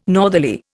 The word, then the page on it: northerly: Indian